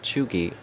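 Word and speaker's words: cheugy: But it says Chugi, pronounced Chugi.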